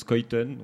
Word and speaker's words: Schuyten: Donc pour toi, ça s'écrit Schuyten, voilà. Ça se prononce Skuyten.